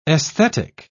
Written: esthetic

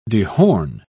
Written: dehorn

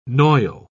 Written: noil

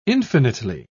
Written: infinitely

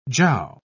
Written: jiao

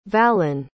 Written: valin

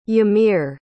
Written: yamir

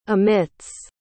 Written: emits

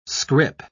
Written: scrip

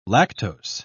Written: lactose

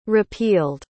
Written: repealed

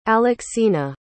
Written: alexina

